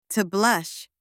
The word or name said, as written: to blush